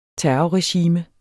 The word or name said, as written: terrorregime